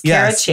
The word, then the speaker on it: Cara Chace: Yes, it's Cara Chace.